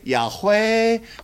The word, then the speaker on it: Yahweh: In English it's YHWH pronounced Yud-Heh-Vav-Heh. Yud-Heh-Vav-Heh, you can say Yahweh.